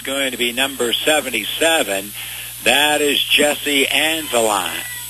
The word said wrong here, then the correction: Angevine: Yeah, I say that wrong. Angevine. We'll get it right.